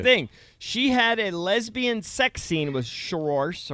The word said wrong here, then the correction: Saoirse: or however you say her name, Cerise, whatever Saoirse. – Saoirse.